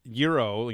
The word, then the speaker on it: gyro: That is a Euro, you know, G-Y-R-O, is an interesting word in that. People think I'm lying when I say it's pronounced gyro. And I'm like, no, that's how Greek people would say it.